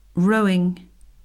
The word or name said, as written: rowing